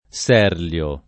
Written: Serlio

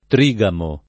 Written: trigamo

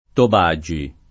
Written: Tobagi

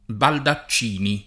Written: Baldaccini

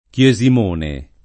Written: Chiesimone

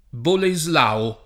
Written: Boleslao

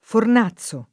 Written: Fornazzo

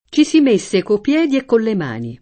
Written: mettere

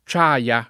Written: Ciaia

